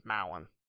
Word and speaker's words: Mowen: And Fallon kind of rhymes with my last name, Mowen.